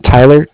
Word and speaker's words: Tyler: My name is Tyler, T-Y-L-E-R, and I'm calling from Bank of Cardiff. It's spelled C-A-R-D-I-F-F, as in Frank.